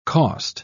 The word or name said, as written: cost